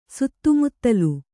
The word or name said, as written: ಸುತ್ತುಮುತ್ತಲು